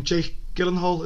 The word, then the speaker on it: Jake Gyllenhaal: Go on, how'd you say his name? Jake Gyllenhaal, is that right? – Yeah.